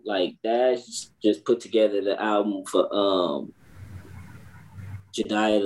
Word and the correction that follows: Judas: the Black Messiah. Did I say it right? – Judas, the Black Messiah.